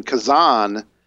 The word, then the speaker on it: Kazan: But I guess you deserve something for, I mean, you played in Kazan. I guess that's how it's pronounced.